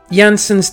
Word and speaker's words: Jansen's: Right, it's Jansen's Temptation, or also known as Jansen's Frestelse. What? It's named after a bloke called Jansen. Spelled with a J though, but they pronounce their J's like a Y.